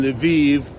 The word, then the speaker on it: Lviv: So, I have noticed something being out here in Lviv, which rhymes with Tel Aviv, because that's where 6 million Jews are gonna come to.